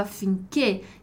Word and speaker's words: affinché: E aqui a gente tem um detalhe de pronúncia do affinché que é escrito com o nosso acento agudo, né? Que em italiano vai indicar o som fechado, affinché.